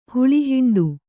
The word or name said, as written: ಹುಳಿಹಿಂಡು